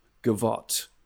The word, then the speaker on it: gavotte: You had one eye in the mirror as you watched yourself gavotte. Who uses gavotte in a song? That's is that the word? I thought it said as you watch yourself go by. – This rhymes with yacht.